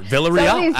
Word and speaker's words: Villarreal: Villarreal, absolutely A-plus stuff.